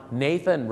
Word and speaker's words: Nathan: Nathan